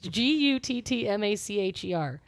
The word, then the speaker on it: Guttmacher: I just said it wrong. It's Guttmacher. Guttmacher.